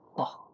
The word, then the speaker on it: Vogt: Pronouncing it wrong last time, and it's Bock. – Yeah. Yeah, technically be more an F sound, you know, whatever.